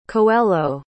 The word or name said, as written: coelho